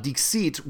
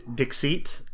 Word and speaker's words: Dixit: I thought it was pronounced Dixit because, you know, it makes it sound like deceit, and it's a game of deception. But then I did some research, and it looks like it's actually supposed to be pronounced Dixit, because it is a French or French-ish pronunciation of a Latin word.